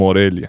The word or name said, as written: Morelia